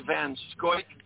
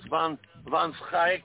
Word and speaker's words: Van Schaik: My last name is a Dutch name, which, you know, we say it here in the U.S., we say Van Schoik, but it's pronounced Van Schaik over in Holland, so it's kind of difficult to pronounce.